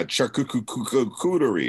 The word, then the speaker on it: charcuterie: Is that how you say it? Charcuterie? – Oh, yeah, charcuterie.